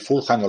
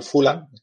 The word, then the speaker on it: Fulham: Luego el Fulham o el Fulan, cada persona lo pronuncia como quieren, que es donde se encontró, que es muy parecido al Pompey, pero tiene muchísima más punta, ¿no?